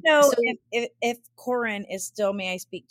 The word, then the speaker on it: Karen: She has an older sister whose name is spelled K-A-R-E-N, as in Karen, but they pronounced it Corin the entire time.